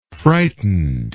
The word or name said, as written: frightened